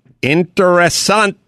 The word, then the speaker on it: interesante: Remember, a little exaggeration makes every story more interesante. I think I said that right. – Perfect.